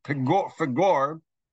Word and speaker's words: Peor: Peor, or probably more properly pronounced, Fagor, uh, is the name of the mountaintop that Bilam went to, one of the mountains of, of Moab.